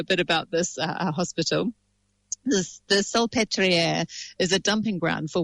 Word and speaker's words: Salpêtrière: but in the L'Hôpital Salpêtrière. How's that? That's very good. Well done. – It's like saltpeter, basically.